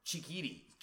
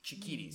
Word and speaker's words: Chikiri: You said it right in the first segment then. – Yeah, okay. – Yeah, cool. You were right, yeah. – I think I said cheeky giddy. I think I added an extra key.